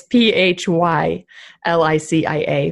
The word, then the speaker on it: Phylicia: It's P H Y L I C I A for Phylicia.